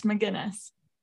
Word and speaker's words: Magennis: Am I pronouncing that right, Erin? – Close, it's Magennis.